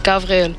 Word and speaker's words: Gabriel: Mein Name ist Gabriel. Von wo ist mein Name? Der Name kommt aus dem Hebräischen und wird Gabriel ausgesprochen.